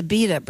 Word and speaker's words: Abita: It's a Abita beer. A-B-I-T-A. – Oh, yeah, yeah. – Isn't that how you say it? – From Abita, yeah.